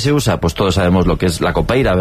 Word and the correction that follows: capoeira: ¿verdad? Capoeira. Qué mal pronuncio últimamente.